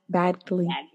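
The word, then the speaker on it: Badgley: You pronounce the D? – Bagley. – Yeah. Bagley. Sorry, sir.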